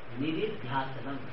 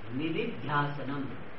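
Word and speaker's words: nididhyāsanam: Now the next sadhana is nididhyasanam. You have to pronounce properly, nididhyasanam. It is not nididhyasana. Many people pronounce nididhyasana.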